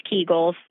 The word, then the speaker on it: Kegels: We'll talk about specifically Kegels. And you are saying it correctly, it's Kegels.